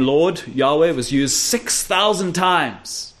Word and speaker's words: Yahweh: Well, they say Jehovah, which is a wrong spelling, by the way. Yahweh is probably the closest pronunciation that we'll have.